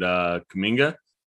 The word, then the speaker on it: Kuminga: They usually try to go with a smaller lineup, but with Looney and Kuminga, I believe that's how you say his name, right? – Yeah, he's not a big guy, but yeah.